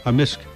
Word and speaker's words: amisk: In amisk. – Amisk. – Amisk. – So the K can be silent, but in general it's...